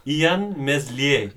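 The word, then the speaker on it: Illan Meslier: And it's Ian Meslier. – Very good. Very good pronunciation.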